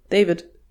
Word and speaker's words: Deywid: Aber ja, deswegen, also der wird genauso geschrieben, D-E-Y-W-I-D, David.